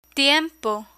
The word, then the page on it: tiempo: spanish